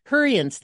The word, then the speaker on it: Hurrians: And we talked about this in one of the other sessions when you were talking about the Huberites. Was that did I get the name right? That had the pits that they were from, like the Hurrians. – Hurrians. Thank you.